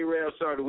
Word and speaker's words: A-Rab Shorty: Yeah, you got to put the English on it, man. DJ A-Rab Shorty, what's happening? – Oh, okay, all right then.